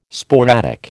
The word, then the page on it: sporadic: American English